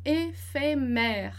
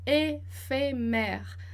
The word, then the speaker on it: éphémère: Então, éphémère, éphémère.